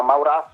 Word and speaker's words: Mauras: si chiama Mauras con l'accento sulla seconda A, è veramente fortissimo.